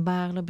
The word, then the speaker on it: Baarle: Zeg ik het goed? – Baarle. Stichting Herdenking Baarle. – Ja.